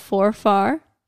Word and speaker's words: Forfar: And a few years ago in 2019, he was made Earl of Forfar.